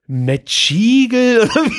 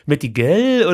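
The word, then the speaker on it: Mettigel: Du wirst nicht glauben, was ich, als ich es heute Mittag gelesen habe, da, ich dachte, was ist denn, wie wird denn das ausgesprochen? Metschigel? Ja, was meint der denn? Das, ich habe halt die ganze Zeit gedacht, das wäre ein anderes italienisches Gericht. Metigel oder? – Met gell? – Metigel.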